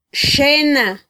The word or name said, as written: xena